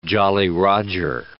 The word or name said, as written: Jolly Roger